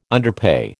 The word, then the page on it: underpay: American English